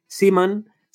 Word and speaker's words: Seaman: Pero es que Seaman se pronuncia igual que semen en inglés.